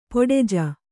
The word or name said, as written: ಪೊಡೆಜ